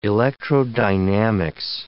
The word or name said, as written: electrodynamics